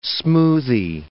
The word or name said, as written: smoothy